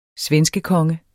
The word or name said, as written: svenskekonge